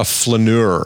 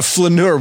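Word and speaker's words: flâneur: A flaneur. Am I saying that right? – You are.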